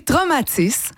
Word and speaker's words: Traumatis: On va tout de suite parler de l'auteur David Goudreau qui va sortir son nouveau roman qui s'appelle Traumatis, c'est comme ça qu'il faut que je le prononce? – Oui, Traumatis.